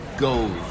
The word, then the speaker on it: Gose: You pronounce it Guz. A Guz would be the French pronunciation. – Oh, he's going French. – Because I heard Gosa. Is Gosa okay for an Anglo?